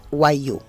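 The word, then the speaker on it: wagyu: Se escribe W-A-G. ¿Cómo era, Eli? – G-Y-U. – Y-U. – Exactamente. – Y guayú. Se pronuncia guayú.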